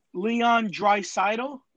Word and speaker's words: Draisaitl: Leon Draisaitl, did I say that right? – Yeah.